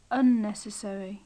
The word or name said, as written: unnecessary